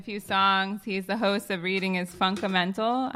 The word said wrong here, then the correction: Funkdumental: I hope I'm getting it correctly. Sal? Funkamental? – Funkdumental. – Excuse me. Funkdumental.